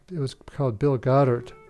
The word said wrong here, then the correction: Gothard: Well, I mispronounced his name. It's actually Bill Gothard, G O T H A R D.